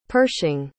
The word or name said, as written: pershing